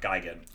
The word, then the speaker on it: Gigan: Godzilla character is pronounced Gigan.